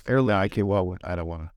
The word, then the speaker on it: Aer Lingus: Aer Lingus through Dublin. – You said it correctly. – Yeah. Aer Lingus.